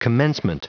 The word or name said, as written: commencement